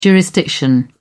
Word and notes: jurisdiction: British English

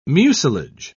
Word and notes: mucilage: British English

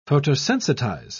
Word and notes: photosensitize: British English

